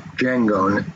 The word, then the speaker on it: Njango: Paulino and Django, N-J-A-N-G-O, Django, if the N is silent.